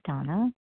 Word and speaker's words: Dana: This is Donna B. My name is spelled D-A-N-A, but pronounced Donna.